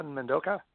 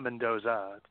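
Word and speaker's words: Mendonça: It's Jason Mendonca, because it's not Mendoza. – I want to say Mendoza for some reason. Mendonca. M-E-N-D-O-N-C-A. Mendonca. – Mendonca. Mendonca, yes.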